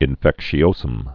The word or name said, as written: erythema infectiosum